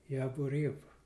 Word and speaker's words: Yavoriv: In the Jaworo district of Poland, which is now in the Ukraine, and spelled and pronounced Jaworiv. In the English alphabet, you would spell Jaworiv, Y-A-V, V like in Victor, Y-A-V-O-R-I-V. In the village of Gnojnice, Poland. And that Gnojnice is spelled G-N-O-J-N-I-E-C-E.